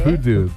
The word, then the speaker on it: Purdue: ¿Cómo se pronuncia? – Se llama Purdue, yo no sé. Purdue. – Soy americano.